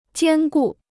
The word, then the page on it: 兼顾: jiān gù